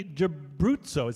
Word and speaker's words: D'Abruzzo: and guest starring the amazing Stephanie D'Abruzzo.